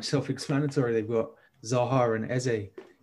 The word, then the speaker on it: Eze: I'm probably pronouncing his name wrong because... – No, yeah, I think you got it right. – You're on the money. – I saw a video of him when he signed going through how to pronounce his name because people would call him Easy.